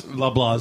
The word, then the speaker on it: Loblaws: Is that the proper pronunciation? Loblaws is? – Loblaws is.